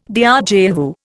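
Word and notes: déjà vu: Indian

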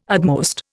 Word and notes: utmost: Indian

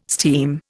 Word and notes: steam: Indian

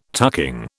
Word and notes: tucking: British English

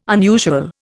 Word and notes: unusual: Indian

